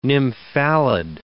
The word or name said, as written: nymphalid